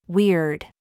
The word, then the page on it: we: American English